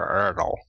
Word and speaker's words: Erdl: I will also say Dr. Erdl. – Dr. Erdl rhymes with turtle for the win. – Rhymes with Yertle the turtle.